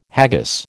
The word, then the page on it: haggis: American English